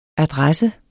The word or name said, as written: adresse